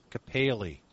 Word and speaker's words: kephale: The word head in Ephesians 5:23 and in 1 Corinthians 11:3 is a word-for-word translation of the Greek word kephale, K-E-P-H-A-L, long E, which means head.